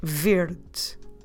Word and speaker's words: verde: Verde. I will spell it for you. V E R D E. But although it ends at E, we drop it when we pronounce it. So we say verde, verde.